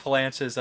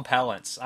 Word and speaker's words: Palance: That's like Jack Palance's like weird MO. I always call him Palance. His name is Palance. I always call him Palance. I always mispronounce his name.